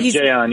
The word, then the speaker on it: Jayon: They're going to be without, is it Jayon Brown? Am I pronouncing his first name properly? Linebacker. – Yep, Jayon, yep.